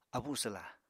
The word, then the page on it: abuswla: Apatani